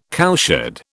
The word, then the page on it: cowshed: British English